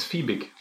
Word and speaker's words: Fiebig: Do I say Fiebig or Fiebig? – It's Fiebig.